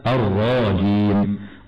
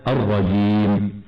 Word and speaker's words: الرجيم: ومن الخطأ أن نقرأ بمد الراء ونأتي بألف بعدها خطأ هكذا الرجيم، والصواب أن تنطق من غير ألف هكذا الرجيم.